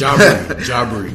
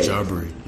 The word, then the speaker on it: Jahbri: I can't say your name right. I don't get it. – It's Jahbri. – Jahbri. Okay. – Jahbri. Yep.